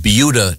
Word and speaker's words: Buda: we have yet to get to sports, but we were discussing a very interesting news item from Beulah, Texas. – Mm-hmm. – Christy, they what have, you insist on saying. – No, that's how it's pronounced. – Okay. – Is it B-U-D-A?